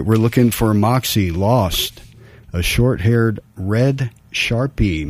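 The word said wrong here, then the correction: Shar-Pei: mix. Yeah, Sharpay. Excuse my pronunciation there.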